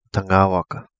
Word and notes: Tangawaka: Kapingamarangi

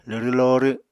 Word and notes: lwrw lorw: apatani

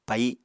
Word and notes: pai: Apatani

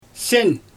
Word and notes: sen: Xyzyl

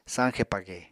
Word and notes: sañxe page: Apatani

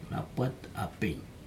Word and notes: napat apig: Aneityum

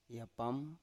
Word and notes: yapam: Biak

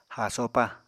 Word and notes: haasopa: Apatani

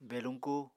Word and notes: beluñku: Apatani

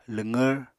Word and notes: lwqvr: apatani

